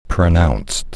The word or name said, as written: pronounced